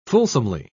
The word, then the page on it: fulsomely: British English